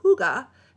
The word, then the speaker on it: hygge: Okay, hygge, which is spelled very differently. – Hygge. – H-Y-G-G-E, but it's said hygge.